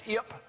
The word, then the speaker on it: Ypsilanti: So I was saying Yip. It's not Yip, it's Ip. They just put an I sound there.